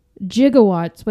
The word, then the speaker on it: gigawatts: So he says gigawatts, but it's actually gigawatts. Scientists, physicists, and that kind of stuff do pronounce it with a hard G, so it is gigawatts.